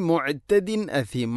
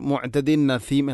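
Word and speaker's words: മുഅ്തദിൻ അസീം (مُعْتَدٍ أَثِيمٍ): തൻവീനു ശേഷം ഹംസ വന്നാൽ ഇൽഹാറാണ് ചെയ്യേണ്ടത്, അവൻ മണിക്കാൻ പാടില്ല. പക്ഷേ നമ്മളൊക്കെ ഓതിപ്പോകുമ്പോൾ മുഅ്തദിൻ നസീം എന്നായിരിക്കും. അധികം ആളുകളും ഓത് ശ്രദ്ധിച്ചു നോക്കുന്നുണ്ടോ? മുഅ്തദിൻ നസീം എന്നായിരിക്കും വായിക്കുക. അത് അറിയുന്ന ആളുകളിൽ പോലും അങ്ങനെ ഉണ്ടാവുന്നു എന്ന കാര്യത്തിൽ ഗൗരവത്തോടെ ശ്രദ്ധിക്കേണ്ട വിഷയമാണത്.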